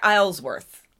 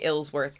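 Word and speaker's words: Isleworth: To me, it looks like Islesworth, but I'm going to say Ilsworth.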